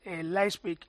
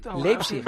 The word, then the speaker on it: Leipzig: Mallorca, Leipzig, ¿lo pronuncio mejor que ayer? – No, igual. – No, muy bien. – Leipzig. – A ver, dilo. – Leipzig. Tú dices Leipzig.